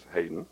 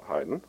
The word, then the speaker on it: Haydn: That is Haydn, not Haydn, although it's spelled the same as the name of the great Austrian composer Franz Joseph Haydn.